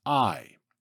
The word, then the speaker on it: Ai: The name of this city was Ai, spelled A-I, but pronounced I.